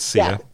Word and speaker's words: Sia: But I know that one of the responses, so is it Sia? Am I saying her name correctly? – Yes, it is Sia, yeah.